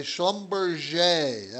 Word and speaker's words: Schlumberger: Schlumberger. That's how you say it, even though it looks like Schlumberger. Schlumberger is Schlumberger.